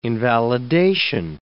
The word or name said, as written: invalidation